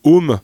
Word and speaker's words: Ohmme: Et alors, assez particulier, parce qu'en fait, le groupe à la base s'appelait Home, alors qu'il se prononce comme un homme, mais O-H-M-M-E.